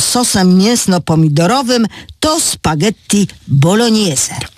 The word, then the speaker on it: bolognese: W oryginale tę nazwę pisze się spaghetti bolognese, co powinno się wymawiać bolognese, bo zbitka głosek G i N daje ni.